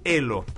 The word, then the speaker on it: Ello: Se escribe Ello, es con doble L, Ello, pero se dice Elo, para conversar acerca de esta nueva red social, qué es lo que significa.